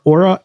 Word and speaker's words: Oura: Aura, O-U-R-A, ring Gen 3.